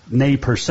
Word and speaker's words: Nez Perce: but I was a Chief Joseph fan from the Nez Perce, or Nez Perce is actually the correct pronunciation.